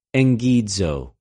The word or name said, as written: ἐγγιζω